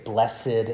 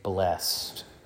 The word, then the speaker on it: blessed: When do you say blessed and when do you say blessed? I'm going to have a very quick pronunciation lesson. Whenever this word is used as a verb, we pronounce it blessed.